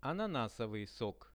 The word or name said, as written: ananasovyi sok